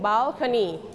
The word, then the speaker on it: balcony: Don't forget to pronounce le sound. Bal. You have to roll your tongue. Bal. คำที่มีตัว L แบบนี้นะคะม้วนลิ้นนิดหนึ่ง Balcony.